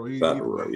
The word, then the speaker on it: Favreau: Especially, you know, I never pronounce his last name. John Favreau, you know, he played Happy Hogan. He's my favorite. – Favreau. – Yeah.